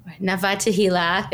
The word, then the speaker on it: Nava Tehila: Nava Tehila, if we're trying to pronounce things in the Hebrew pronunciation, the Sephardic pronunciation.